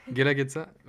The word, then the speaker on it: Guelaguetza: Euh, qui s'appelle la Guelaguetza, je ne sais pas si je le prononce bien. Euh, est-ce que tu peux nous... – Guelaguetza, ok. Est-ce que tu peux nous,